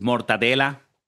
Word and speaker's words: mortadella: And it was amazing with mortadella, how do you say mortadella? Just mortadella, right? – Mortadella, yeah.